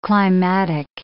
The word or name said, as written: climatic